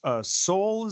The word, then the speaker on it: Soule: Charles Soule is the way they pronounce his last name. I always thought it was Sewell, but I don't know, they call it Soule.